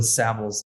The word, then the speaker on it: Savills: I like to say travels with Savills. That's a good way to remember the pronunciation of the brand.